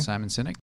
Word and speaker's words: Simon Sinek: And it came from a TED Talk from Simon Sinek. Is that how you say it? That's right, Simon Sinek.